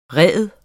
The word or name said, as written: REDENES